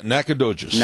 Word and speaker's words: Nacogdoches: Yeah, Nacogdoches.